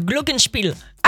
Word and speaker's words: glockenspiel: glockenspiel, je pense que c'est comme ça que ça se prononce, et ça ressemble à un xylophone en fait. – Le glockenspiel.